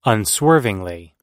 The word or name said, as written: unswervingly